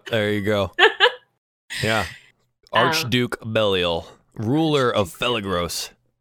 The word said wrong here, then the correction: Phlegethos: Oh, it's Phlegethos. I can't pronounce anything. It's Phlegethos.